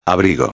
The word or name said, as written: abrigo